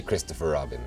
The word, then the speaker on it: Kristoffer Robin: Yeah, you can just say Kristoffer Robin.